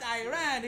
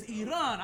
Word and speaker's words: Iran: but you disrespectfully pronounce it wrong. It is not Iran, it is Iran.